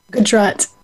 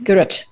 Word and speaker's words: Gujarat: Identifies as a first-generation South Asian American woman with roots in, am I saying this right, Gujarat, India? – Gujarat. – Yep. Gujarat. Okay, you'll have to, you know, help me be more pronunciation specific.